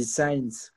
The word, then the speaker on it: Sainz: Em, y yo como en Instagram y Twitter es arroba rub, de las primeras tres letras de mi nombre, Rubén, y Sainz, que se escribe S-A-I-N-Z, arroba rub Sainz.